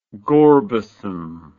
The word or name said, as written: gorbasm